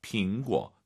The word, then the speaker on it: 苹果: 果 올라가면 안 되죠. 뒤에가 사성이니까 반삼성. 苹果.